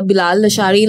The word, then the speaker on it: Bilal Lashari: Like, I feel like Bilal Lashari, like, actually, am I pronouncing his name right? – Yes.